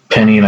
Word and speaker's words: JCPenney: J-C, or capital J, capital C, capital P-E-N-N-E-Y. – Yeah. – Penny, like the coin.